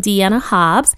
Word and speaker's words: Dianna Hobbs: And if you want to see the video and you haven't, you can watch it on YouTube under my name, Deanna Hobbs. That's spelled D-I-A-N-N-A-H-O-B-B-S.